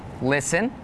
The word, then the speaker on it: listen: Some examples with a silent T are the words listen, the word castle, and the word Christmas.